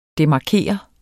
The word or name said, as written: DEMARKER